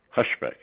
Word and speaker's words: Hushbeck: Dirk, D-I-R-K, Hushbeck, H-U-S-H-B-E-C-K.